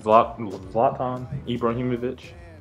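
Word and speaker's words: Zlatan Ibrahimović: Zlatan Ibrahimovic.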